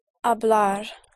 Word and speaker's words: hablar: So to speak would be hablar. – There's only one push. – Hablar. – Hablar, right. Yes. And to eat. – Comer. – Comer, right. Comer. C-O-M-E-R.